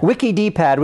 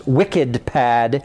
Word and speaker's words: WikidPad: WikiDPad, which is actually pronounced WickedPad.